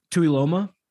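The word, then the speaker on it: Tuiloma: Toyoloma is kind of the way I've heard it pronounced. So that was close enough, you know.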